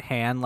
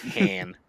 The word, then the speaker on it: Han: I love that you pronounce it Han like Lando. – Yeah, Han.